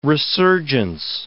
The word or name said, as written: resurgence